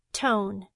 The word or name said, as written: tone